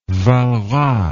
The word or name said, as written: Walras